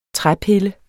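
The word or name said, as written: træpiller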